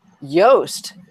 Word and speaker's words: Yoast: We were in on the Yoast is his actual pronunciation of his name, the SEO guy.